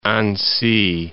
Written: Annecy

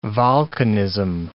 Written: volcanism

